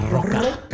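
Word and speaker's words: Larroca: It's being written by Philip Kennedy Johnson, who did Empire Captain America, with art by Salvador LaRocca. God, I hope I'm pronouncing that last name right. Who's done Doctor Doom. – I don't think you rolled the R hard enough. – LaRocca.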